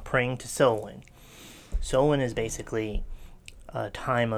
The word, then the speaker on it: Samhain: And we're talking about, as you said earlier, it's a Gaelic word, and it's some people will say Samhain or Samhain, but in several of the pronunciations that I read, it's called Samhain.